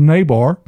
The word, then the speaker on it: Niebuhr: And then, but usually people agree that Reinhold Niebuhr, does that say it right? – Mm-hmm.